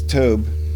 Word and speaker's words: Tobe: Yeah, hi, my name is Tobe, rhymes with toad, and I listen to you guys all the time.